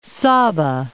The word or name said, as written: Sabah